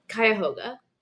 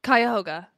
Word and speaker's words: Cuyahoga: Cuyahoga. – Cuyahoga, yeah.